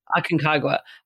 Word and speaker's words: Aconcagua: How do you say the name of that mountain? – Aconcagua, yeah.